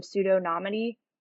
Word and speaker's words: pseudonymity: How do you see, for example, I know President Xi in China banned public blockchains back in 2021 due to fears of pseudonymity. Did I say that correctly? Pseudonymity? – Close enough.